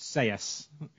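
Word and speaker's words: Sellés: I know I pronounced it Ruben Selles, and I know it's actually pronounced Seyes, but see, Gary Lineker got it right on Match of the Day.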